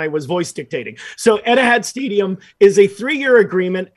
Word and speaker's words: Etihad: I'm probably saying this wrong, Bill. Is it Etihad Stadium? Am I saying that correct? – I think so.